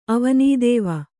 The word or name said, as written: ಅವನೀದೇವ